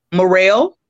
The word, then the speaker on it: Murrell: Christy, C-H-R-I-S-T-I-E, Black, like the color, Morrell, M-U-R-R-E-L-L.